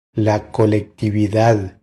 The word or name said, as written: La colectividad